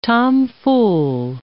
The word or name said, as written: tomfool